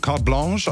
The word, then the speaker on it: Carte Blanche: und wird aber, ja, Carte Blanche, also französisch ausgesprochen.